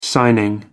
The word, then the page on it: signing: American English